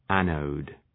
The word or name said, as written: anode